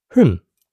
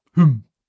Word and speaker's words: Hymn: Welch ein furchtbares Wort zum Aussprechen. – Ja. – Kannst du es auch mal machen? – Hymn. – Hymn. – Nicht so wie die finnische Gothic Band.